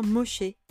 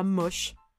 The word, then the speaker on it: Moshe: Du coup, ça se prononce bien Moshe. C'est Moïse en hébreu et pas Moche, j'y tiens.